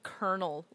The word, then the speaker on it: colonel: In my notes as well, I have it written as kernel, like a popcorn kernel, just to remind me to pronounce it that way.